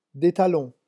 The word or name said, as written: des talons